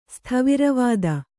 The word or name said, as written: ಸ್ಥವಿರವಾದ